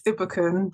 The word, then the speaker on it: Ibukun: It's Ibuken, but I appreciate you trying. – I tried. – Sorry. No, it's okay, you tried. I appreciate that. Hi, my name's Ibuken Olumoreti.